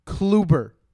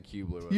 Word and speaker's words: Kluber: If you're going to put him on such a high pedestal, please pronounce his name right. Corey Kluber. – Kluber. – Kluber. – I was saying Kubler.